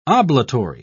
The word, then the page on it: oblatory: British English